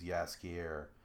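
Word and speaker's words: Jaskier: But the original version is Yaskier, which is how they pronounce it or how they say it in the Netflix show.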